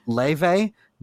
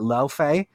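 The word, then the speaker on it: Laufey: It's pronounced Leve, not Laufe.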